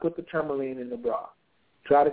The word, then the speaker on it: tourmaline: So okay, with the black tourmaline, that's how you pronounce it.